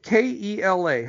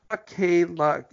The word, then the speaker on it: Kela: Kela? How do you pronounce that? Keone? – K-E-L-A. – K. It's not Kela. It's Kela.